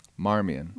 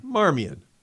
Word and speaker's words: Marmion: So let me just first off mention this blessed, who I'm not going to pronounce correctly, but I'll pronounce it, and Father will correct me. It's called Columba Marmion. – Marmion. – Marmion.